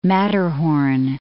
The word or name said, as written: Matterhorn